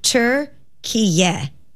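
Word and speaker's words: Türkiye: The official pronunciation of the country's recognized name is Turkiye.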